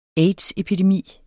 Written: aids-epidemi

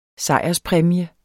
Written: sejrspræmie

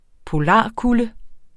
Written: polarkulde

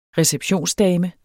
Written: receptionsdame

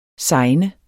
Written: segne

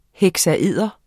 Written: heksaeder

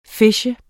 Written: phishe

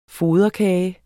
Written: FODERKAGE

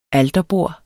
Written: alterbord

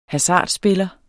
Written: HASARDSPILLERNES

